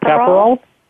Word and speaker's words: Capperauld: And I'm hoping I'm pronouncing your name correctly. Is it Capperauld? – Capperauld? Correct, that's it.